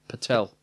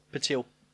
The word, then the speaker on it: Patil: Pavarotti and Pamina Patel. – Patil.